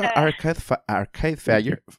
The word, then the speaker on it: Arcade Fire: ¿A ti te gusta Arcade, Fire? – Arcade...